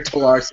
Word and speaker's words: terroir: It's spelled T-E-R-R-O-I-R. And of course it comes from the French, so the French have dropped like multiple letters when it comes to pronunciation.